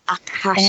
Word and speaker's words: Akashic: Um, uh, it's Akashic Records. Akashic. And um, yes, it comes from the, uh, a Sanskrit word called Akash, which is um, that from which it means that from which everything is formed.